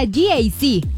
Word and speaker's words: GAC: Se escribe GAC, pero se pronuncia GAC.